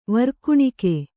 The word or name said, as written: ವೀರಗಾಣಿಕೆ